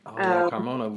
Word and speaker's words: Carmona: And then actually, I might mispronounce her last name, like Olga Carmona, I want to say. – Olga Carmona with Real Madrid. – Yeah, yes. Yeah.